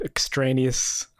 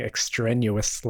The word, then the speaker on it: extraneous: So, first of all, I must apologize for my continual mispronunciation of the word extraneous. I kept saying extraneous, like it's strenuous.